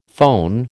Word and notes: foehn: American English

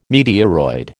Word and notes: meteoroid: American English

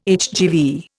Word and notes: HGV: Indian

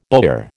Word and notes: bowyer: American English

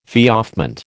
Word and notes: feoffment: American English

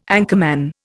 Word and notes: anchorman: Indian

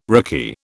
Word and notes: rickey: British English

